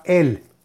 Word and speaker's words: El: La primera, según la página de Facebook Kabbalah y Torah, hay un nombre sagrado para meditar que es el nombre Aleph Lamet. Se pronuncia él.